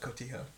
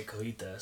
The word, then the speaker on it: cotija: I'll definitely top it off with cotija. – Okay. Mm-hmm. Interesting. – See, I always say cojita, so I guess I'm definitely saying it wrong.